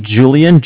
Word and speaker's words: Julian: It's Julian, J-U-L-I-A-N.